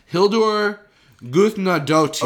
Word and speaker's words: Hildur Guðnadóttir: Hildur Guðnadóttir.